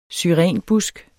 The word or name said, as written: syrenbusk